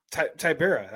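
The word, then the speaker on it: Tybura: I'm going to call him Biggie Boy Rosenstruck versus Tibera. – This is just a horrible card for pronouncing names. Horrible up and down. – Tibera. That's how you say his name.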